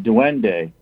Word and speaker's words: duende: Duende. Duende. So D-U-E-N-D-E, but it's pronounced duende.